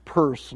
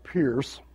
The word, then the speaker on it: Peirce: Pronounce it Peirce, not Pierce. And I think I spelled him wrong there, too. I think his name is P-E-I-R-C-E, not P-I-E-R.